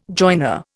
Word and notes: joiner: Indian

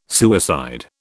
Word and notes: suicide: British English

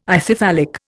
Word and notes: Ithyphallic: Indian

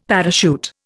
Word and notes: parachute: Indian

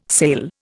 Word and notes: sail: Indian

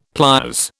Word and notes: pliers: British English

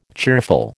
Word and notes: cheerful: American English

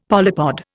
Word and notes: polypod: Indian

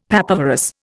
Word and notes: papaverous: Indian

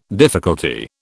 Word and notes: difficulty: British English

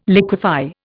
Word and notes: liquify: Indian

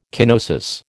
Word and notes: kenosis: American English

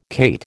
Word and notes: cate: American English

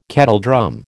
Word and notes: kettledrum: American English

